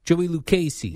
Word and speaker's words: Lucchesi: Joey Lucchesi.